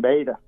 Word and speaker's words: Bahada: But I called the macaws. I said, how would you pronounce this in your language? And Bahada is what I was told.